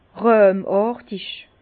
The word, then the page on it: ràhmàrtig: Bas-Rhin; Herrlisheim